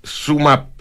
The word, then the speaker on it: SumUp: Se escribe Sumup, ¿ya? S-U-M-U-P, pero se pronuncia Sumap.